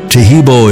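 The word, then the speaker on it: Taheebo: Tehebo is spelled T-A-H-E-E-B, like boy, O, then the word T.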